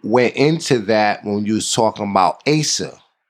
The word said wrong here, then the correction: ACEs: Is that how you could pronounce it? It's called ACEs? – Yep, the ACEs study.